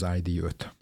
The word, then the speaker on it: ID: Tavaly évben vezettük be az ID4-et, ami szintén nagyon jó fogadtatásra talált, és ennek a kupésított verziója lesz majd az ID5. – Gyakorlatilag mondhatjuk ezt, hogy olyan, mint a Lego, ez a Volkswagen sorozat, az ID sorozat, vagy ID sorozat, ahogy angolosan ejtik.